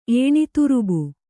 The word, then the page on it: ಏಣಿತುರುಬು: Kannada